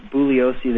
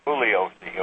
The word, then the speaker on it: Bugliosi: His name is actually pronounced Bugliosi. The G is silent. – Bugliosi.